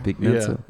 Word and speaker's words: Vic Mensa: Vic Mensa.